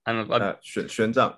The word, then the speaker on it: Xuanzang: like Xuanzang, is it? – Xuanzang, yeah. – Xuanzang.